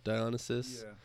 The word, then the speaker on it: Dionysus: There was there was some cool variety in some of the production that I liked, essentially, like, or I know Dionysus, is that how you say it? Yeah, Dionysus.